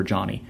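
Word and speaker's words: Johnnie: I tell everybody that it's Ozark for Johnny.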